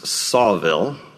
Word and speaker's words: Saltville: In a southwestern town in Virginia, and I was corrected by one of our pastors, it's spelled Saltville, but it's apparently pronounced Sawville, okay?